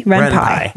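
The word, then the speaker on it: Ren'Py: Renpy.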